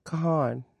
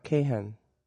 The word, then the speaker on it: Kahan: Noah Kahan, it's pronounced Noah Kahan, not Kahan.